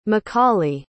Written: mccauley

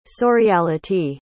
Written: surreality

